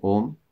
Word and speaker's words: Om: When we say Om, and then coming by closing the mouth, then that is the correct way to say Om. And so it is Om without any tension, just the mouth is open, that's all.